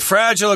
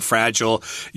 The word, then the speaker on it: fragile: Fragile, of course, means easily broken. That's the American pronunciation, fragile. You will hear Brits and Canadians saying fragile. Those are two different pronunciations. They're both correct.